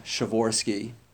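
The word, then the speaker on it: Przeworski: That's how you pronounce it, by the way. The P-R-Z-E-W is just sure, you know, there's a kind of P at the beginning that you don't actually say, but it's not just Shavorsky, it's Shavorsky.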